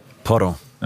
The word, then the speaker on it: Porro: Da kannst du jetzt wieder glänzen mit der Aussprache, nämlich Pedro Porro. Porro.